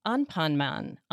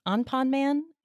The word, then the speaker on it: Anpanman: I wanted to start out asking you actually about a short video that I just saw on your YouTube channel about Anpanman. Anpanman? – You know Anpanman.